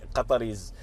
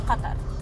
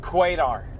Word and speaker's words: Qatar: Quatar. – No, you're joking. – I swear to God. Quatar! – Americans? – Cutter? – Irish.